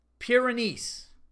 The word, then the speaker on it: Pyrenees: It's Pyrenees. The dog. It's pronounced Great Pyrenees.